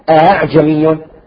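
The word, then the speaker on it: أأعجمي: الهمزة المسهلة أن تلفظ بين الهمزة والهاء، لاحظتم؟ الهمزة المسهلة تلفظ بين حرفين. الآن سأذكر عندما نصل إلى التسهيل بالتفصيل. في عند حفص له عدة كلمات مسهل، وهي كلمة أعجمي، هي أصلها أعجمي، فسهل الهمزة الثانية فأصبحت أعجمي أعجمي.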